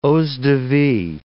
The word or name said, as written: eaux-de-vie